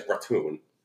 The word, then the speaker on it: ratoon: Besides ratoon. – Ratoon is correct.